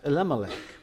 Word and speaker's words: Elimelech: The word Melech there means king. We pronounce it Elimelech.